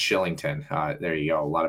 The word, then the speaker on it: Kylington: It's a Irish or Scottish name, and it's spelt Kylington, but it's pronounced Shillington for whatever reason.